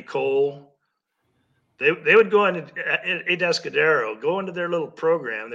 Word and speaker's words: Atascadero: Atascadero, have I pronounced that right? – Atascadero, yeah.